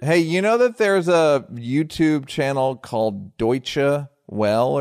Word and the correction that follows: Deutsche Welle: Deutsche Welle.